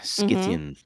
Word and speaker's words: Scythian: The Scythian steppe, It's spelled S-C-Y-T-H-I-A-N, Scythian or Scythian.